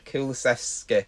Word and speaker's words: Kulusevski: Kul, Kul, can never pronounce it right. Kul, Kulusevski.